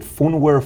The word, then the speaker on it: Phunware: your, is it Phunware, Phunware, am I saying it properly? – P-H-U-N, yeah. – P-H-U-N.